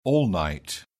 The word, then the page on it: all night: British English